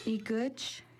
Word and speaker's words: Igutch: Igutch is how you say nose in Kixt.